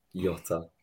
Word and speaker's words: Jota: I thought Jota, or whatever his name is, made a meal of it. It'd be a silent J, by the way. A silent J. – Jota.